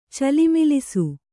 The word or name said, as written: ಚಲಮಲಿಸು